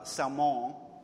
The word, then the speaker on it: Salmon: Well, Rahab marries a guy named Salmon. It's the French pronunciation, spelled Salmon.